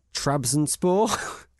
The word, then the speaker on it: Trabzonspor: Trabzonspor.